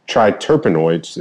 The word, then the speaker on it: triterpenoids: It's greater than 4% on the reishi triterpenoids.